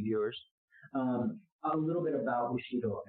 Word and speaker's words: Bushido: Bushido. Did I pronounce that correctly? – Yeah, sure. – Bushido. – Like you're boo and you're like hot Cheeto, you're Bushido.